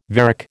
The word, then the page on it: varec: American English